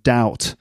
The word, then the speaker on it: doubt: Anyway, the the thing here is that uh, the pronunciation of doubt. Uh, it does have a B in it, D O U B T, but the B is silent, so it's doubt, not doubt. Okay, doubt. Silent B.